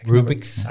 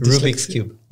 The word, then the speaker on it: Rubik's: Mm which could manipulate the cubics, the Cubic's cube. I forget. – Rubik's. I, Rubik's. – I can never pronounce it. – Rubik's cube.